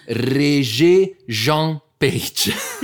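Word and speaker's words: Regé-Jean Page: Poi abbiamo, oddio mio, io non, io non ce la faccio questi nomi, abbiamo Regé Jean Page.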